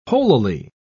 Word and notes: holily: British English

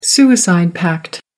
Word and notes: suicide pact: American English

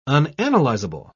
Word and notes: unanalyzable: British English

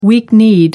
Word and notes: weak-kneed: American English

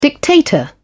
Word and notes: dictator: British English

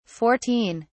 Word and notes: 14: British English

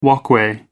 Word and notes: walkway: American English

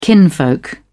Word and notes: kinfolk: British English

